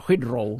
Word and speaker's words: Heathrow: Heathrow. – Ah, Heathrow, sí.